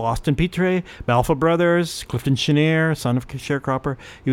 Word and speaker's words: Chenier: Chenier, maybe you think you should pronounce it, but in the United States, they called it Chenier. Clifton Chenier. That's how he was introduced always.